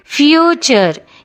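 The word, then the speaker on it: future: ಫ್ಯೂಚರ್ F U T U R E S ಅಷ್ಟೇ ಅಲ್ಲ, ಟಿನ ನಂತರ ಕೂಡ ಯು ಆರ್ ಇ ಬಂದಾಗ ಅದು ಚ ಅಂತಲೇ ಉಚ್ಚಾರ ಆಗುತ್ತೆ. ಅದಕ್ಕೆ ಫ್ಯೂಚರ್.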